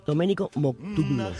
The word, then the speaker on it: Domenico Modugno: Domenico Modugno.